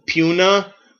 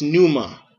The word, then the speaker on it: pneuma: It sounds puna, but it's pneuma. It's pneuma. The P is silent.